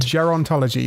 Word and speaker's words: biogerontology: Well, to tackle that problem, a new field has emerged, and that's one of biogerontology, which I think I've absolutely butchered. – Biogerontology, that's what I can do. – Biogerontology, there we go.